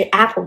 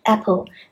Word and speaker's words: apple: 我经常跟学生说，你要想象你要吞下一只鲨鱼那么大，那个就是 apple 的 a 的发音。对，那你肯定要张大。如果你小呢，就是 apple，那就完全不一样的感觉。